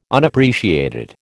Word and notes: Unappreciated: American English

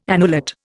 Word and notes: annulate: Indian

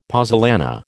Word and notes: pozzolana: American English

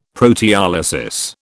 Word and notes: proteolysis: British English

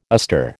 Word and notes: astir: American English

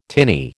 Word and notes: tinny: American English

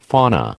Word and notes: fauna: American English

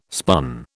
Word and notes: spun: British English

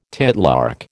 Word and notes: titlark: American English

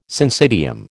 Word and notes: syncytium: American English

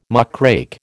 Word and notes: muckrake: American English

